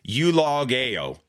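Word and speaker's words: eulogeo (εὐλογέω): Bless is from a Greek word meaning, this is how it's pronounced, eulogeo, which is where we get the English word eulogy.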